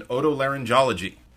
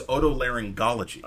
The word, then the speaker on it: otolaryngology: Amy says, the extra J is not to be found in otolaryngology. The first G is hard. The jology part is... I'm pronouncing it wrong. The word is otolaryngology. – Oh, otolaryngology. – Yeah, the first G of the gology part has the same consonant sound as golly G.